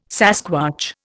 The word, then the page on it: sasquatch: Indian